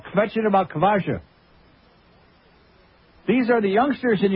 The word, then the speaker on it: Kvasha: Kvasha, am I saying that right? – Kvasha, yeah.